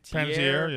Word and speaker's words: Panettiere: But, like, I always mispronounce her last name, Hayden Panettiere. – Panettiere, yeah.